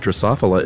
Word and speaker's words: Drosophila: I want to say that Chuck's pronunciation of the word Drosophila is correct.